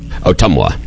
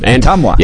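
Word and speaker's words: Ottumwa: Otumwa. – Otumwa.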